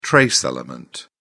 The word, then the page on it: trace element: British English